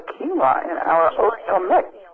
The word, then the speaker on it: quinoa: It's spelled Q-U-I-N-O-A. It's pronounced quinoa, like K-E-E-N-W-A, quinoa. – Q-U-I-N-O-A. – Q-U-I-N-O-A.